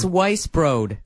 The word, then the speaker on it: Weisbrod: Myself and Kelly Krull, who is with CSN, narrowly beat Mark Carmen, the evil Mark Carmen, and Brooke Weisbrod. It's Weiss Broad. – Right. – That's what it is. – That's who it was. – Like Weiss Beer and Broad rhymes with Road.